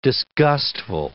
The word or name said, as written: disgustful